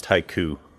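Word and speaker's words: Hico: It's actually right next to a place called Heiko, where Billy the Kid died. – It's pronounced haiku.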